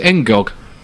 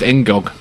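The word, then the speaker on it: N'Gog: Apparently, you pronounce it Engog. – Do you? – Yeah, there's no fancy. It's just Engog.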